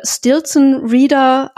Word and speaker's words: Stelzriede: Also es geht um eine Familie, wir würden sagen, Stelzriede, so schreibt sich das. – Mhm. – Ähm, er sagt im Buch, äh, das Ganze würde Stilton Reader ausgesprochen. Das ist dann die amerikanische Aussprache.